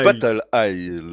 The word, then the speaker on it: Battle Isle: Donc Battle Eye. – Battle Isle.